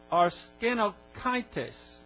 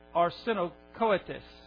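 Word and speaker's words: arsenokoitēs: And the Greek word for that is arsenokaites. I'll spell that for you. A-R-S-E-N-O-K-O-I-T-E-S. A-R-S-E-N-O-K-O-I-T-E-S. I guess that would be arsenokoetes.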